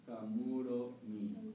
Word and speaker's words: Kamuromi: Esto también es importante pronunciarlo bien. Muchas veces oye Kamurogi, Kamurogi. Es Kamurogi, Kamuromi.